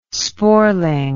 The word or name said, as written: sporeling